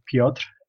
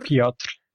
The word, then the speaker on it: Piotr: he didn't call me Piotr,